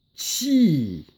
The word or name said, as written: Calligraphy